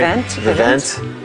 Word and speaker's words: Vivint: The home security company, Vivint. Is that how you pronounce that company? – Vivint.